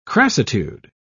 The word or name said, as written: crassitude